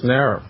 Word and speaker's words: Naira: Is it pronounced... How is it? Naira? – Yes, Naira.